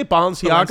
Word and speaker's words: Balenciaga: How do you say Balenciaga? – Balenciaga.